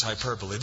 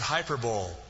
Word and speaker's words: hyperbole: My friend who's a pastor is still convinced that you pronounce it hyperbole, but it's hyperbole. It's not like the Super Bowl and the Orange Bowl and the Hyper Bowl.